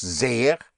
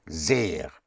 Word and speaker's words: sehr: And in sehr, for very, it is pronounced sehr, so you have S-E-H-R, sehr. Without the H, it would be sehr. Sehr, stretched out with an H.